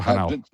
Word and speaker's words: Hanau: He's been DJing for 35 plus years, I believe, from Frankfurt am Main, now living in Hanoi. I think I said that right. Well, Hanau. – That's close, yeah. Hanau.